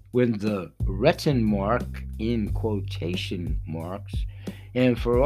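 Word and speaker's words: Rentenmark: potato, potato, whatever, it's spelled R E T E N M A R K. And I'm going to stand by Renten Mark and have at it.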